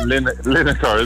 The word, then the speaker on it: Lineker: It rhymes with vinegar. – Or Lineker, isn't it?